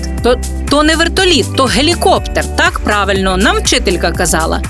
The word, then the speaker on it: гелікоптер: Гелікоптер наголос саме на останньому складі,